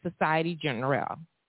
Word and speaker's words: Société Générale: I was pronouncing it the way it's saying in France, but it's Société Générale.